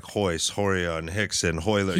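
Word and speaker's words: Rickson: Hoist, Hickson. Yeah, I mean, like there are other Brazilian names that start with R, pronounced H, but they're names you would recognize, like Rogers, like Roger, you know.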